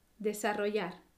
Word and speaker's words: desarrollar: Como ves, Aquí la combinación de ciertos sonidos, como la R fuerte y la doble L, puede causar dificultades de pronunciación. Así que puedes empezar pronunciando sílaba por sílaba y despacio. De, sa, ro, yar. Desarrollar.